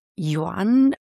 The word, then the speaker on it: Yuan: Und zwar ganz einfach, deinen Vornamen schreibt man mit Y-U-A-N. Und wie spricht man den richtig aus? – Ja, mein Vorname ist Yuan. Oft wird es mit J ausgesprochen. Du hast ja ganz richtig ausgesprochen mit Yuan.